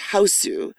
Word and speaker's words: Hausu: if you if you want to see the wackiest early early Japanese horror, you watch Hausu. It's it's actually house, but it often is seen as H A U S U, as the more phonetic uh, translation. – Pronunciation yeah. Japanese.